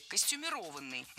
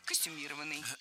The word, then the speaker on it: костюмированный: Несколько раз прозвучало прилагательное «костюмированный» с ударением на третьем слоге. Однако такое ударение в слове было не всегда. Долгое время оно считалось неправильным, а литературной нормой был вариант с ударением на четвертом слоге – «костюмированный». Но так никто не говорил, и филологи прислушались к гласу народа, норму изменили, и теперь правильное произношение – «костюмированный».